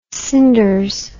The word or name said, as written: cinders